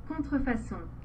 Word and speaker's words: Contrefaçon: Let me get the pronunciation from Google here. Here we go. – Contrefaçon. – Contrefaçon. So I was close.